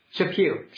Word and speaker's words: Chaput: is it Chaput? Is that how you pronounce it? – Chaput, sure.